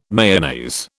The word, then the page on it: Mayonnaise: British English